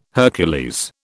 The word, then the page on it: Hercules: British English